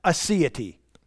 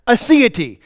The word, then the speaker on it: aseity: aseity. Yeah, that's right. Aseity. It's spelled A-S-E-I-T-Y. Aseity.